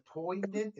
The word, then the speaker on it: poignant: And I'm not going to say it's a poignant. Is that how you say it? P-O-I-G-N-A-N-T, poignant? – Poignant, yeah.